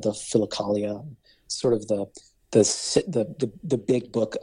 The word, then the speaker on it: Philokalia: It's Philokalia is how it's spelled. So Philokalia is how it's pronounced.